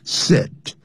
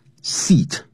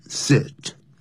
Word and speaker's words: sit: sit 不要念成 seat、啊、短元音 sit 好 it doesn't matter who will do the job 只要这工作被做得很棒谁做的工作都无所谓。